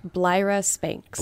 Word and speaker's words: Blyra Spanks: Blyra Spanks. – Blyra Spanks. – Yes, rhymes with Tyra Banks.